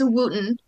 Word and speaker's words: Wooten: It's Wooten.